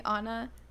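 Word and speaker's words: Ana: But no, like my name is spelled A-N-A. I mean fuck A-N-I. Fuck. A-N-I because it's not. So in America, I say like "yo," or any English-speaking country, I say just call me Anna, because my actual name is Ani without the dot on top of the I.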